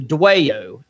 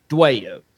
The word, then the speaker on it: Dwayyo: So also in the same area of Maryland / West Virginia, there's legends of a creature called the Dwayo, and that's spelled D-W-A-Y-Y-O, Dwayo.